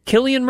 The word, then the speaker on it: Cillian: And it was, and I love the fact that Cillian Murphy, is that how you say his name? – I believe so, yes.